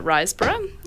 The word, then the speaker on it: Riseborough: I thought the main performance by Andrea Riseborough, is that how you pronounce it? – Yeah.